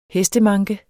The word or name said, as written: hestemanke